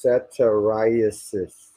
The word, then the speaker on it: satyriasis: It's pronounced satyriasis.